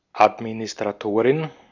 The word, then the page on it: Administratorin: /ˌat.mi.nɪs.tʀaˈtoː.ʀɪn/